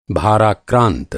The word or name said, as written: भाराक्रांत